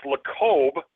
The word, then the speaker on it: Lacob: Apparently, as the quote goes, Golden State Warriors owner Joe, I believe it's pronounced LaCobe. It might be LaCobe, but there's no vowel on the end. It's just LACOB.